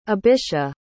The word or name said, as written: abisha